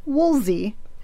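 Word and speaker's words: Woolfardisworthy: Woolfard is worthy is actually Woolsey.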